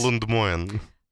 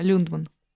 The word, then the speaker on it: Люндман: Вот, э, кстати, все спорят, как правильно фамилию произносить. – Она и я всегда говорил раз... Лундмоен. Да, но она несколько раз э, в своих выпусках называла ее, поэтому... Да, Люндман.